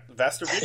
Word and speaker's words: Västervik: Yeah, and I think you mentioned that you were going to be heading is it Västervik? Am I saying that right? Västervik? – Yeah, exactly.